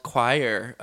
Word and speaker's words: Xoir: So I wanted to start this episode by talking a little bit about something that I do called Choir. It's spelled X-O-I-R,